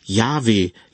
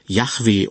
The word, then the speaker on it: Yahweh: Der eigentliche Gottesname, der im hebräischen Originaltext steht, müsste wohl Yahweh, Yahweh oder Jehova ausgesprochen werden.